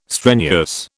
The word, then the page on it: strenuous: British English